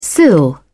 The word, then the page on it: sill: American English